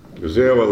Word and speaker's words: Guzėjeva: Bet mane, paskutinį metu žiūrint, ta laidos vedėja Guzėjeva, ar taip pavardė? – Guzėjeva Larisa. – Guzėjeva Larisa Guzėjeva ištarė tokią frazę.